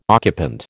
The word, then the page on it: occupant: American English